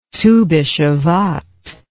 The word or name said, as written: Tu Bishevat